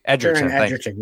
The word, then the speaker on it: Egerton: Is that how you say his name? Egerton. – Taron Egerton, yeah.